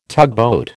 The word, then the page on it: tugboat: American English